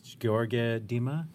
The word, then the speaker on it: Gheorghe Dima: Is it Gheorghe Dima? – Perfect.